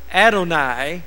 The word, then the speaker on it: Adonai: And that primarily has to do with the Father, said unto my Son, and that is Adonai. A-D-O-N-A-I is the way it's spelled, Adonai.